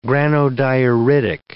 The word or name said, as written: granodioritic